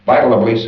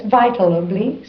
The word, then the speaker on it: vitally: I always say vitably. You see, and you can't distinguish the L in it, although I think I'm saying the L. Always. So, no, it's vitally seriousness.